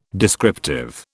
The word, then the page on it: descriptive: British English